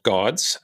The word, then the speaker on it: GAWDS: a NFT project called GAWDS, uh, G A W D S, for those who are interested in Googling. But I like that you pronounce the W.